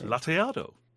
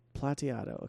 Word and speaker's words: Plateado: How do you pronounce the Nitmex? – Plateado. – Plateado. Okay, perfect.